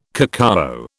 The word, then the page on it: cacao: British English